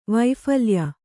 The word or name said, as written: ವೈಫಲ್ಯ